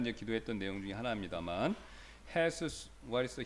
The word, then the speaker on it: Jesus: So his name, his first name is pronounced Jesus, but it's Jesus, and then his last name is Palomo, which means is Spanish for dove.